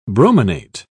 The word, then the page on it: brominate: British English